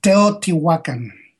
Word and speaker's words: Teotihuacan: Is it Teotihuacan? How do you say that down in Mexico? – It's Teotihuacan. – Teotihuacan.